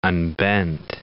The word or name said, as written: unbent